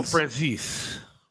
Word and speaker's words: Franzese: Michael Franzese.